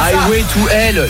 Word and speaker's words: Highway to Hell: C'est I Hail to Hell. – Non mais I Hail to Hell. Non mais n'importe quoi. – Voilà, Highway to Hell, là, l'autoroute vers l'enfer.